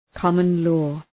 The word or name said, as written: common-law